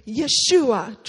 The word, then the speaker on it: Yeshua: That's it. Yeshua. Try to say that with me.